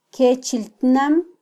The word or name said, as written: kyechiltnam